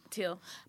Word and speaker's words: Teele: How do you say her last name? – Teal. – Malik Teal, actually from you.